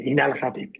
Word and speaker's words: Ibn al-Khatib: Y ahí es donde surge el protagonista de tu novela, Ibn al-Khatib. No sé si lo he pronunciado bien. – Sí, Ibn al-Khatib, exactamente.